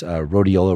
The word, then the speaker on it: rhodiola: And then the other supplement is rhodiola rosacea. Am I pronouncing that correctly? – Yeah. So yeah.